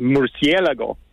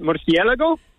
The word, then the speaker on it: murciélago: It is pronounced murciélago. – Oh, thank you. – Can All you right. do it? Murciélago? – Murciélago.